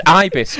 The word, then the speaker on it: Ibis: It's pronounced Ibis, Chris.